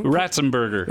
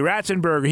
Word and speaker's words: Ratzenberger: Ratzenberger. Thank you, Ratzenberger.